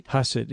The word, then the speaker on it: Hasid: Is that how you say it? Hasid? – Hasid, yeah.